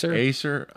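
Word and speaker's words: Ausar: I don't even know how to say his name. Auser Thompson? – Acer.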